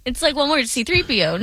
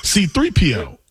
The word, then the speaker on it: C-3PO: Your emphasis is on the C. – C-3PO. – Mine's is on the 3. Mine's on the 3. P. C-3PO. – But you're making it sound like two different words. It's like only two different one word.